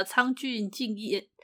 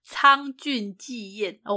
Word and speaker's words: 倉薗紀彦: 对，行商人呢，作者是那个苍俊敬彦。苍俊纪彦哦，我的发音刚刚有点糊在一块。